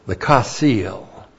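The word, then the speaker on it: chasil: C-H-A-S, long I-L, kasil. All right, a couple of things on the kasil.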